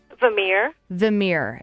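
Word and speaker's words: Vimiere: Vimere, and that's V-I-M-I-E-R-E.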